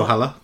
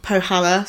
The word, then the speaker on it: Pohjala: Next, I'm going to put pronounce that for me. – So Pohjala. – Pohjala, so that I'm not, you know, getting cancelled myself.